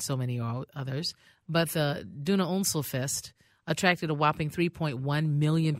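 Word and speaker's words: Donauinselfest: I looked and listened on two websites, and I hope this is right. But it's Duna Unselfest.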